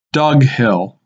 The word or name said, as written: Dug Hill